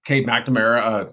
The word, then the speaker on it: Cade McNamara: We saw him up in Michigan kind of split time with, I'm blanking on his name now, Cade McNamara.